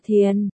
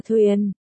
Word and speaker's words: Thiền: Chính là Thiền, ta quen đọc là Thuyền.